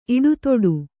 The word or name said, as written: ಇಡುತೊಡು